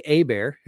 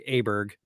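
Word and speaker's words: Åberg: Ludwig Aber, who I guess it's I've been saying Ludwig Aberg, because I know, we've all been saying it wrong.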